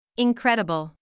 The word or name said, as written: incredible